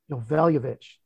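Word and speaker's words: Joveljić: Early Saturday games, I've got Yovel. Noveljevic, that's how you say it.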